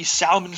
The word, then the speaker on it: salmon: But did you notice that he kept pronouncing the L in salmon? – No, I didn't actually. – Yeah, we're here at the salmon fair.